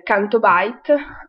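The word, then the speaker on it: Canto Bight: Canto Bite, almeno io lo, la pronuncio così, poi è scritto Bigt, quindi penso che si dica Byte, eh, che è questa specie di, come dire, Las Vegas spaziale, con il casino e tutto quanto, eh, quindi una...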